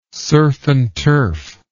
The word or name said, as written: surf n turf